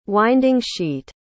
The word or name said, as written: winding-sheet